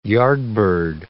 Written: yardbird

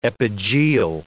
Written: epigeal

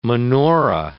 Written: menorah

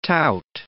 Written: tout

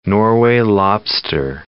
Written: Norway lobster

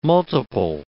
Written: multiple

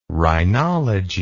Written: rhinology

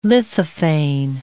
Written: lithophane

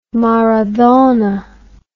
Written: maradona

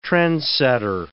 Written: trendsetter

